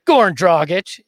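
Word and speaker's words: Goran Dragić: Number three, not going to be a surprise to anybody who's listening to this show, Goran Dragic.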